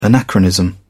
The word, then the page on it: anachronism: British English